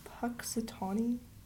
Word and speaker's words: Punxsutawney: Anyway, so there's no A after the W. – So that would sound like Punxsutawney.